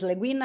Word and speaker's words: Le Guin: I hope the pronunciation is Le Guin. I mean, as far as I'm aware, it is. Don't worry.